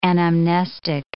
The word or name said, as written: anamnestic